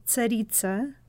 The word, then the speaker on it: Tsaritsa: Because originally, we have to be honest, we were talking Tsarina, but you corrected us that we have to use the correct pronunciation. – Well, slightly, yes. There is a Russian word, Tsaritsa, originally, which means the woman in power of royal blood.